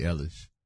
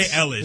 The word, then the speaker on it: Eilish: But I got to pop my collar a little bit because I did call out the fact that Billie Eilish was going to sweep up.